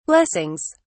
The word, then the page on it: blessings: British English